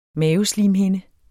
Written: maveslimhinde

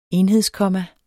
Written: enhedskomma

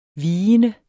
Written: vigende

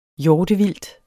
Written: hjortevildt

